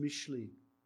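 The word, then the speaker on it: Mishlei: In the Hebrew, it's pronounced Mishli.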